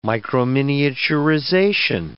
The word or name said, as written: microminiaturization